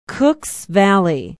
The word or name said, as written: Cooks Valley